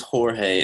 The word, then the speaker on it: Jorge: Some people call him George, other people call him Jorge. Anyway. – Actually, the correct pronunciation is Jorge, and if you don't get it right, you're canceled.